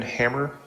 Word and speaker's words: Hammer: For the record, my name is Evan Hammer. It's spelled H-A-M-M-E-R, and I'm the Digital Library Administrator at the Montana State Library, available for any questions you may have.